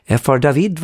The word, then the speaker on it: F.R. David: F.R. David var det före det, med Words från 1982. Ja, franskt uttal, för han är från Frankrike.